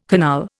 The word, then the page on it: canal: Indian